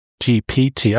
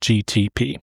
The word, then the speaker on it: GPT: We've talked about them before, but this time I had my personal assistant, ChatGTP. Did I say it right that time? – GPT. – GPT. I don't know why I keep calling it GTP.